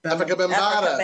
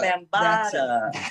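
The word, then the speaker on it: Afrika Bambaataa: Africa Bambaataa. – Africa Bambaataa.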